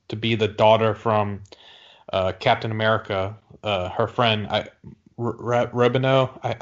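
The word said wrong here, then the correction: Rambeau: I can't pronounce it. – Captain Marvel. – It's Rambo, like First Blood.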